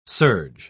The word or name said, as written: surge